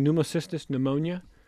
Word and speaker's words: Pneumocystis pneumonia: Did I pronounce that correctly? Pneumocystis pneumonia? – Very good.